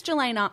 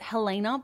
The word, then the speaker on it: Jelena: I think it's Jelena. I want to pronounce it Helena, but it's not Helena because with a J.